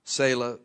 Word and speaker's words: Salah: Arphaxad begat, Genesis 10, 24, Arphaxad begat Salah, and Salah, it's pronounced, Salah begat Eber, and unto Eber were born two sons, okay, Peleg and Joktan.